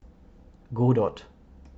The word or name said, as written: Godot